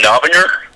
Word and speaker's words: Novinger: Uh, my last name is Novinger, uh, but it can be pronounced in like 17 different ways.